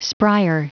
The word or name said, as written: spryer